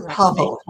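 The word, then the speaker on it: Paweł: It's Pavel. The W is a Pavel. Okay. Yeah. You do say Pavel.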